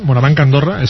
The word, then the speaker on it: MoraBanc Andorra: Francesc Solana, director deportivo del Morabanc Andorra, lo he dicho bien, Morabanc Andorra, es como se llama.